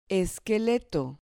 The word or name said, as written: esqueleto